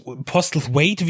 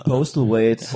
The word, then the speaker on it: Postlethwaite: Äh, und natürlich, äh, Jason Isaacs spielt mit, äh, Pete Postles, Postles Wade, wie spricht man den aus eigentlich? Äh, genau. Postlethwaite.